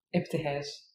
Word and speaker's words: Ibtihaj: Okay, and how do you pronounce her first name? Tehaj. – Ibtihaj. Yeah, gotcha. Also, I said it the very Arab way.